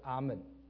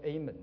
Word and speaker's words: Amen: 另外一个字就是阿门，阿门也是他原来的发音啊，美国人说 Amen 的 A 错了哈，阿是他原来的发音，阿门是阿对了哈。